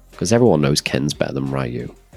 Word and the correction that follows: Ryu: Oh, sorry, Ryu. Ryu. Sorry, that's like 30 years of saying his name wrong.